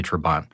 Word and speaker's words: Trabant: Now, the Trabant, and I've heard that pronounced a couple different ways. I say Trabant.